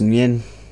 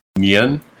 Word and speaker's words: Nguyen: So the driver's name is Nguyen, like N-G-U-Y-N. – That's pronounced Nguyen. – Nguyen.